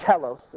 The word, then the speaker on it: telos: It's the word telos. That's how you pronounce it.